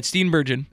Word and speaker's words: Steenburgen: Is that her name? – Mary Steenburgen. Why will tell her how you say her name? – I think you're right. Steenburgen. – Steenburgen's her name.